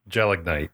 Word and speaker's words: Gelignite: Gelignite.